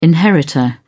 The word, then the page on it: inheritor: British English